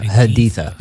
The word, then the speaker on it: Haditha: So Haditha.